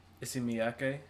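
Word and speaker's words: Issey Miyake: Isimiyake.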